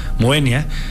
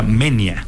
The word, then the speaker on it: Moenia: Dijimos, Moenia. Ya luego después nos enteramos que se pronuncia Menia, porque es diptongo, entonces va junto.